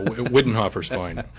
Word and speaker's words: Widenhofer: By the way, Widenhofer, am I pronouncing that correctly? – Exactly. – Okay, because there are a couple of some families, that's a name that you see around here, and there's another pronunciation that some families use. I just want to make sure I was in the right family. – Yeah, Widenhofer's fine.